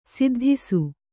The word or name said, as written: ಸದ್ದಿಸು